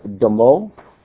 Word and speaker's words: damah: It's the word demo. It's a three-letter word. You'd spell it in English D-A-M-A-H. In Hebrew, it's dalet mem he.